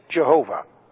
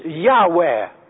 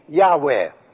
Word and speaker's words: Yahweh: that it is a mispronunciation of God's name, Jehovah. It's too bad for the Jehovah's Witnesses. They have it all wrong. You see, it is Yahweh, spelled a Y, and then an A, and then an H, and W, E, and then another H. Yahweh.